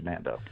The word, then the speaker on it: NandO: Okay, so real quick, is it pronounced Nando or N-and-O? – Well, Nando, okay. But it is made from, you know, locally the News Observer was referred to as the N-and-O.